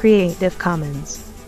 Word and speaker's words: Creative Commons: Bueno, tú que sabes cómo se pronuncia, dilo, anda. – Creative Commons.